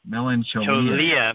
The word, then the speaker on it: melancholia: Melanchia. I'll have to remember that as a disorder. Are you suffering from melanchia? – Melanchia. Cholia. – Cholia. Melancholia.